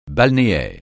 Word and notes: balnéaire: francais

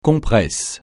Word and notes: compresse: francais

